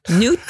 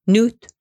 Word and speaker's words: Nuit: Nuit Blanche again. Done this one before. Still drinking it. N-U-I-T-S. How do you say that? Nuit. – What Blanche. Did you say? I'm terrible. – I know it's hard. N-U-I-T-S. N-U-I-T.